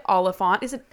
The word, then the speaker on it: Oliphant: Anyways, so today in studio, we have the lovely Tray Oliphant.